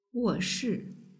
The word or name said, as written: woshi